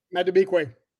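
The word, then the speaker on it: Madubuike: I would have to say it'd probably be Robert Jones, with Justin Maduaki. I don't know how to say his name. Maduike? – Maduike. – Say it again. Tell me. Maduike. Okay. All right. Look at you.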